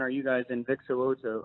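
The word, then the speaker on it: So'oto: And you got his name right, Vic Soto.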